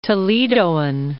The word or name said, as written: Toledoan